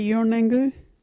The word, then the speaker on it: Yurnanggu: Yurnangu is spelt Y U R-N-A-N-G-G-U. Can you say Yurnangu?